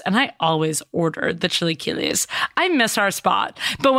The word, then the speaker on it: chilaquiles: These are for her chilaquiles. Like, how do you pronounce it? Chilaquiles. – Chilaquiles.